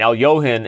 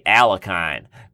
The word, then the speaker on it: Alekhine: So saying Alyohin, a lot of people, I mean, Alyohin is spelled Alekhine. Americans say Alekhine, but it's like when Americans say Kasparov instead of Kasparov.